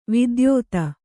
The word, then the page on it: ವಿದ್ಯೋತ: vidyōta